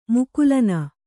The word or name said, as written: ಮುಕುಲನ